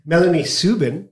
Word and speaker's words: Melanie Subin: We have today with us Melanie Subin, if I pronounce it correctly.